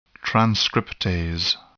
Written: transcriptase

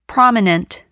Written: prominent

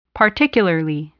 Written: particularly

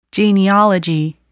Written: genealogy

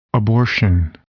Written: abortion

